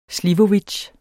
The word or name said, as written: slivovits